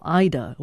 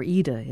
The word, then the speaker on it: Ida: Jørn Harum named the fossil Ida, or Ida, as he pronounces it.